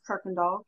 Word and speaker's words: Kirkendoll: And my last name is Kirkendoll, K-I-R-K-E-N-D-O-L-L.